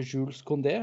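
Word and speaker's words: Jules Koundé: Pau Torres og Jules Condé.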